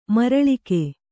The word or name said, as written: ಮರುಳಿಕ್ಕು